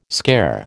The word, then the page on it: scare: American English